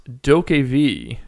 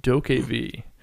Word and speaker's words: DokeV: It's pronounced Dokev, just so everyone knows. Dokev. D-O-K-E, capital V.